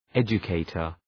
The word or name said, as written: educator